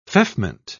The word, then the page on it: feoffment: British English